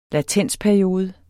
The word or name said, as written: latensperiode